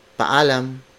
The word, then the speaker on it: paalam: And then when you say goodbye, you would say in Filipino, paalam, paalam, paalam. It is spelled as P A A L A M. You may notice paalam features a double vowel. There are two A's at the first part of the sentence.